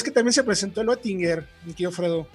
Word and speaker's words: Oettinger: Tienes que decir la O y poner la boca como si dijeras E. Es algo así como Oettinger. Pero bueno. – Oettinger.